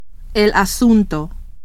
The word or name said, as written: asunto